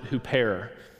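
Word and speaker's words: huper (ὑπέρ): This Greek preposition, you would pronounce it huper, and it is a marker indicating the action of the subject in somebody else's interests, all right?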